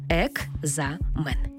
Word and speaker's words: екзамен: Слово «екзамен» пишемо з літерою «К». «Екзамен». А як ми його вимовляємо? «Екзамен». Наче чується звук «г».